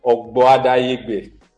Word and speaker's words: Ogboadayegbe: The surname is pronounced Ogboadayegbe. – Ogboadayegbe. – Yes. – Interesting. What does that mean? – Well, Ogboadayegbe is an Edo name.